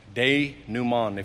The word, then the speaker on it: denouement: Denouement.